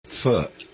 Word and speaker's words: feu: Feu. Yeah, it's F-E-U and pronounced Feu, rather than Fe or Foo. But that's the mispronunciation.